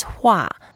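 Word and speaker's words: hua (化): Transformation is pronounced as hua. – Hua. – Yeah, it's the fourth tone.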